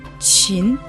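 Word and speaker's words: 禽: Слово «чин» произносится во втором тоне.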